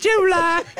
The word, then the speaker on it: July: Hey, just get the second syllable out. – Calm down. – You know what? Yes, it can be July if you don't spend so much time on the first syllable.